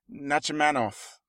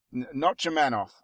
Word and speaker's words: Nachmanoff: So it's directed by Jeffrey Nachmanoff. Nachmanoff, I think, is how you say it.